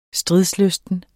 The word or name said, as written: stridslysten